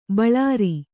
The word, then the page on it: ಬೋಳರೆ: Kannada